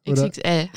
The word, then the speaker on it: XXL: Du bist zuständig für Beratung, Begleitung, Jugendprävention, auch an Schulen, Antidiskriminierungsarbeit für die, wie spricht man es aus, XX-Stelle? – XXL. XXL, XXL Aachen.